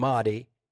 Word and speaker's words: Mahdi: And you talk about the Mahdi. Am I saying that right? The Muslims, yep. – Mahdi, they have a state of anticipation for that Mahdi.